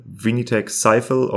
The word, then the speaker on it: Vinitech Sifel: It is spelled V-I-N-I-T-E-C-H-S-I-F-E-L. Vinitech Sifel, or how you pronounce it in Francais, Vinitech Sifel.